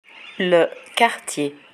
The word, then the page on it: le quartier: french